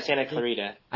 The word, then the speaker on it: Santa Clarita: Yeah, Santa Clarita.